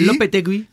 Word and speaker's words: Lopetegui: Wo man auch sagen muss, übrigens, ähm, ich kann den Namen zwar nicht aussprechen, hier Lopetegui. – Lopetegui.